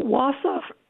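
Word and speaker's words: Wausau: So Wasaw, for example, comes from the Ojibwe word for far away or distant. How would you say that yourself? – I would say Wasa.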